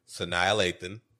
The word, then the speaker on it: Sanaa Lathan: So this movie starts with a simple question from our voiceover, which is Sydney, played by Sanaa Lathan.